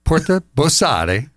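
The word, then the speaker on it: Porta Borsari: Porta Bossare. Bravo. – And the Porta de Leone. That's a good one. Okay, there you go. But the Porta Bossare was where everyone who entered the city to sell into the markets had to pay their fees.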